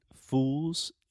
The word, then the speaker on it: fools: so, a quick note on pronunciation fools is a very difficult word for a lot of Brazilians to pronounce because it has the final L sound, which in linguistics, if you are a linguistics nerd, we call that the dark L.